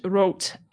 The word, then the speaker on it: Roth: And for anyone who doesn't know, the Challenge Rot, and how am I going with the pronunciation, by the way? – Really good, my friend.